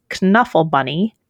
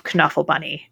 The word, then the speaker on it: Knuffle Bunny: And Trixie is insisting on pronouncing the K, which got me to change my pronunciation of Knuffle Bunny.